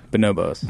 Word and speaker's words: Bonobos: Although if you go to the, how do you pronounce it? Bonobos? – Bonobos. – B-O-N-O-B-O-S. – Like the chimp. – B-O-S.